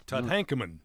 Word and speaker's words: Tutankhamun: Tutankhamun.